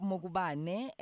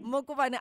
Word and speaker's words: Mokubane: Koketso Mokubane.